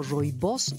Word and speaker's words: rooibos: cuyo nombre se pronuncia como roibos y que significa arbusto rojo.